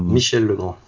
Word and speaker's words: Michel Legrand: Michel Legrand.